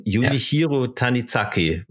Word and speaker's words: Junichiro Tanizaki: Deswegen kommt die Frage, ist aus dem Buch Embrace of Shadows von Junichiro Tanizaki. Habe ich das richtig gesagt jetzt? Ja, genau.